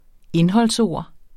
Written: indholdsord

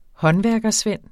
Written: håndværkersvend

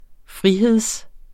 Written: frihed

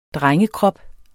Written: drengekrop

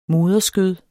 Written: moderskød

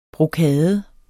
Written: brokade

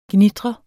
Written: gnitre

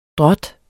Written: drot

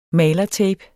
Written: malertape

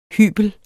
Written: hybel

